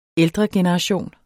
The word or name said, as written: ældregeneration